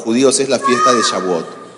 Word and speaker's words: Shavuot: Shavuot, que se escribe S-H-A. La B chica, la V, la U o T, Shavuot.